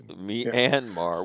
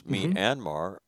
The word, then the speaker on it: Myanmar: Myanmar. Yeah. Well, it's pronounced. Yeah. It's written Myanmar. So if you look at them as me, as in me lie, right? As separate syllables, Myanmar.